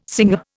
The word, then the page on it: singer: Indian